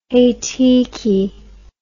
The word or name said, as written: heitiki